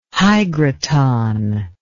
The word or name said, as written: hygroton